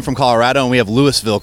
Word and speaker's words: Louisville: The S is silent apparently here. But they always say talk like you've had a few bourbons, and that's how you say Louisville properly.